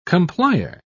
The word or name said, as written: complier